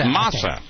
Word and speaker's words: masa: Which is just the mesa. I know I'm not pronouncing that properly. – I think it's masa. – Masa. – Masa. That's the Spanish pronunciation, dude.